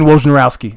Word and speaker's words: Wojnarowski: Adrian Wojnarowski.